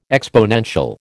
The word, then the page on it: exponential: American English